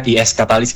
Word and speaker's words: IS Catalyst: Iya, thank you banget nih, kita ngobrol bentar nih soal apa, IS katalis atau IS katalis apa gimana nih bacanya? – Oke, itu dibacanya IS katalis Kak, atau kepanjangan dari Information System Catalyst.